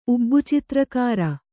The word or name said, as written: ಉಬ್ಬುಚಿತ್ರಕಾರ